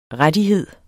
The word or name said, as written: rettighed